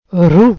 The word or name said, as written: roue